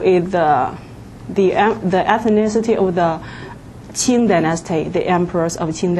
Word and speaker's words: Qing: Qing. – Q-I-N-G. – The last. Okay. – The other Q is pronounced like TS.